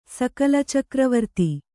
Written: ಸಕಲಚಕ್ರವರ್ತಿ

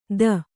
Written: ಥೂ

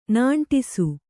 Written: ನಾಂಟಿಸು

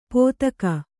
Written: ಪೋತಕ

